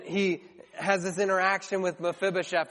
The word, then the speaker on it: Mephibosheth: His name is actually pronounced Mephibosheth.